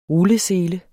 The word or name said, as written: rullesele